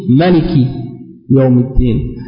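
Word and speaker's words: ملك يوم الدين: Tandis que dans une autre récitation, on le prononce sans le alif. On dit Maliki Yawmiddin.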